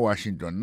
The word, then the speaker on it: Washington: Si on a mis accent sur accent encore, on va voir que le mot Washington, l'accent tombé sur le premier syllabe là.